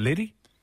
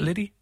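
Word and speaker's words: Lidi: It's L I D I. Is it Liddy? Would I be pronouncing that? Is it Liddy? Liddy? Liddy? Yeah, Liddy. That's a cute name.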